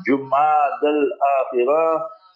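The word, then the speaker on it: Jumada al-Akhirah: Hence, tonight will usher in the month of Jumad al-Akhirah. So lesson number one, know how to pronounce the month correctly. So tonight will be Jumad al-Akhirah.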